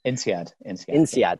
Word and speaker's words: INSEAD: So he did his undergrad at the University of Oxford in physics and got his MBA at INSEAD. I believe that's how I pronounce it. – INSEAD.